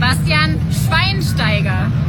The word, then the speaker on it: Bastian Schweinsteiger: Bastian Schweinsteiger.